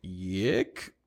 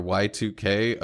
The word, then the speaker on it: YIIK: And we are currently playing through Yik or Y-I-I-K or Y2K, a postmodern, it must be pronounced that, yeah, pronounced production note, it's pronounced Y2K, but also calling it Yik is kind of funny. So yeah, Y and then Roman numeral 2K.